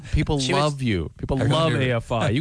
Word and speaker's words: AFI: Davey Havoc, Jade Puget, AFI, to say it right that time.